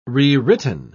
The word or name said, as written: rewritten